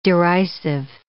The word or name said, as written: derisive